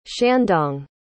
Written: shandong

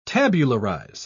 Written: tabularize

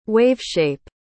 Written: waveshape

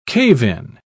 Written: cave-in

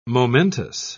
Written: momentous

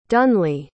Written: dunleah

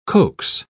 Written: coax

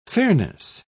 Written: fairness

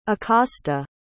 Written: acosta

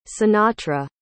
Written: sinatra

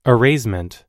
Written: erasement

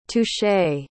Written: touché

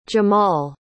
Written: jamal

